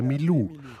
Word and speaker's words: Milou: Aber er konnte Marie-Louise nicht aussprechen. Also sagte er Milou.